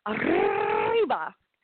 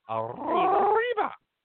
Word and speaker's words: arriba: I can't believe you don't know how to roll your R's. I mean, seriously. – Arriba. – Jeez. Arriba. Come on, like a telephone ringing. – Arriba.